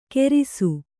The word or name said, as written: ಘೇರಿಸು